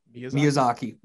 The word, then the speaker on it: Miyazaki: Did I say it right? – Miyazaki. – Miyazaki. Mm-hmm. Oh, see, nope. Miyazaki films.